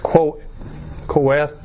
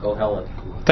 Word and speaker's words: Koheleth: Okay, the Hebrew word, and not that I can pronounce it. You know, I have enough trouble with the English word. It's Koheleth. – Koheleth.